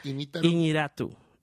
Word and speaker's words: Iñárritu: So this year, it's Iñárritu. Mm. Am I saying that right? – Iñárritu.